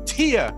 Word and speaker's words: Thea: Yes, T-H-E-A is pronounced Tia. This whole time, I was pronouncing her name wrong, Thea, and she just corrected me.